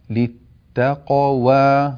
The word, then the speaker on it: للتقوى: أيضا ننبه أيها الأحبة للتقوى، للتقوى نقلقل القاف الساكنة ثم ننطق حرف الواو بعدها، للتقوى للتقوى، فلا تكون القلقلة مع حرف الواو، للتقوى لا، نبين القاف الساكنة المقلقلة ثم ننطق الواو المفتوحة بعدها.